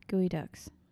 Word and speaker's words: geoduck: Gooey ducks. – The hell is that? – Never seen a gooey duck? – No. – You probably look that up. – It's spelled like geoduck.